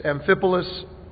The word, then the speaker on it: Amphipolis: It's Amphipolis, Apollonia, and Thessalonica. Okay, now you have the lesson. Now you can read it with me. Verse 1, let's read. Now, when they had passed through Amphipolis and Apollonia, they came to Thessalonica, where there was a synagogue of the Jews.